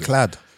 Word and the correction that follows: manacled: M-A-N-A-C-L-E-D. – Manacled.